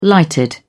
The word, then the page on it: lighted: British English